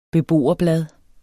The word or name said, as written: beboerblad